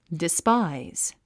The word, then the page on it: despise: dispáiz; ディスパァイズ